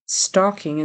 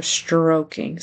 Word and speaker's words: stroking: Then he says stalking instead of stroking. So he needs to get that R there and a long O, stroking.